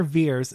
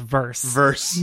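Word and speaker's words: Vers: and that's not how you pronounce it. I am on Grindr enough to know it's pronounced verse.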